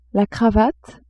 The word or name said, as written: cravate